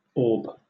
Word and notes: orb: British English

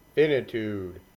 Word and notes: finitude: American English

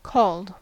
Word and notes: called: American English

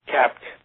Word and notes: capped: American English

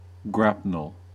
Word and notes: grapnel: American English

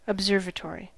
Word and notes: observatory: American English

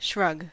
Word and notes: shrug: American English

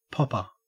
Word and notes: popper: Australian English

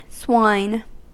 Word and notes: swine: American English

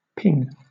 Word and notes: ping: British English

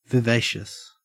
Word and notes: vivacious: Australian English

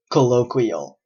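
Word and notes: colloquial: Canadian English

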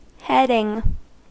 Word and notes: heading: American English

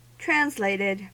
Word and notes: translated: American English

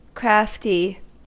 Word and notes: crafty: American English

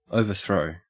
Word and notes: overthrow: Australian English

